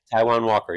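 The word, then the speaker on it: Taijuan: Taiwan Walker, yep.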